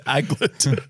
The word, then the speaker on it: aglet: That's so the anglet goes through. The eyelet. – You're still saying it wrong. It's an anglet. – Yeah, there's no way. – It's aglet. – A-G-L-E-T.